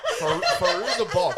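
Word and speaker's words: Fairuza Balk: Firuza Balk. – Fiona Applegate. – Yeah. No. Faruza Balk.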